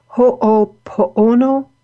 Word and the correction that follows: Ho'oponopono: teachings? Ho'oponopono. okay.